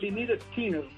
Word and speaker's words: Lynyrd Skynyrd: pronúncienlo así para que vean, se pronuncia un poquito más Lynyrd Skynyrd para nosotros los cubanos, artífice de éxitos como Free Bird y Sweet Home Alabama.